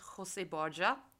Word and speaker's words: José Borja: Der hieß José Borja und sein Buch heißt Así Sufre Latinoamérica. – Und das hast du jetzt krass schön ausgesprochen.